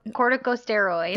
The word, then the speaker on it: corticosteroid: How do you say that? Cortico- corticosteroid. Thank you.